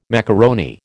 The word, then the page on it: macaroni: American English